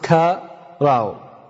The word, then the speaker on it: qara: And it is spelled in English the way it spells it in this book, Q-A-R-A, and it is pronounced ka-ra-u.